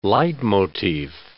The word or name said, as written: leitmotiv